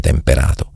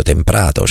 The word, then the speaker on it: temprato: praticamente mi ha detto non si dice temperato, si dice acciaio temprato, cioè è una correzione, e io la correzione l'accetto quando la correzione è giusta.